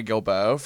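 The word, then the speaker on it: Guilbeaux: But one of my favorites is Mary Gilbo from American Idol. And that's G-U-I-L-B-E-A-U-X is how you spell it, of course.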